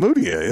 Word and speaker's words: Mudiay: All Emmanuel Mudiay, how do we say his last name? – Mudiay. That's how I say it.